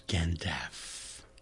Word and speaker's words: Gandalf: As is Gandalf. Rhymes with half.